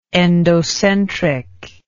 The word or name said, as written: endocentric